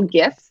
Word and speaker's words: GIF: Cause he would send like sexual gifts, you know, like GIFs. Is that how you pronounce that? – Yes. GIF, yep. GIF?